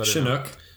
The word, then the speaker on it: Chinook: So this one is made of Mosaic, Citra, and Chinook hop. I think that's how you pronounce it. Is that right? Because double O, so Chinook.